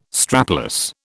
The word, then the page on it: strapless: British English